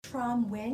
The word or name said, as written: Tram Nguyen